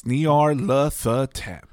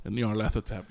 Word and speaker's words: Nyarlathotep: It's Nyarlathotep. – Nyarlathotep, yep.